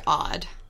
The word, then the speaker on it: Aud: Her real name is actually Odd. – O-D-D? – It's spelled A-U-D, but it's pronounced O-D-D.